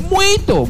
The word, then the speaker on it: muito: Muto. – ¿Cómo? – Muto. – Portugués se dice muito, muito, muito, muito, muito, muito, muito, muito, muito, mucho se dice muito.